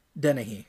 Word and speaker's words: Dennehy: Danny? Diney? I don't know how to pronounce that. – Ned Dennehy? – Dennehy. Okay, yeah.